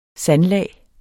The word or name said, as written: sandlag